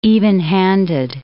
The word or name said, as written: evenhanded